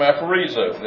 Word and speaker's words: aphorizo: And incidentally, this word is aphoreza. Now, I haven't pronounced it correctly, Matt. It's a form. It's a Greek form, aphorizo.